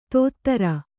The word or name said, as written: ಧೋತ್ರ